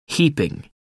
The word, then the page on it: heaping: American English